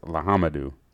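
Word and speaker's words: Le Homme Dieu: It's L-E space H-O-M-M-E space D-I-E-U. So I'm going to call it Lahamdu. – Hey, you're really close on that one. It's Lahamdu.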